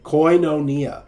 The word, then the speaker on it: koinonia: But I believe this one is correctly pronounced koinonia,